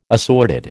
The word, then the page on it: assorted: American English